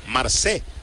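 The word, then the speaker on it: Marsee: Jacob Marcy, con doble E, Marcé.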